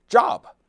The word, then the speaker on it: Job: 约伯这个人呢，在英文当中的拼写是 J-O-B，这字大家都认识， Job，找工作嘛， This is my job。但是在圣经当中呢，他的名字的读音念 Job，有点像我们说那姓周啊，翻译成中文叫约伯。